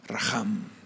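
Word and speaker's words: racham: Se escribe en español, se escribe R-A-C-H-A-M y por lo que he visto se pronuncia Raham. En el hebreo viene, ah, pues no tienen vocales, entonces es R-H-M.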